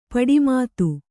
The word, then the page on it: ಪಟುಮತಿ: Kannada